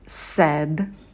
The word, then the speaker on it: said: То же самое со звуком «э» в слове «said», «said». Рот минимально открывается, и мы этот звук произносим из улыбки.